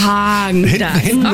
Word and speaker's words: Häagen-Dazs: Spricht man das mit R oder mit A aus? – Man spricht es mit A aus. Ich sage auch, es heißt Häagen-Dazs. – Häagen-Dazs.